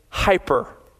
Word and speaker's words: hyper: Beyond here is the Greek word huper. We use this word in English, but we pronounce it hyper.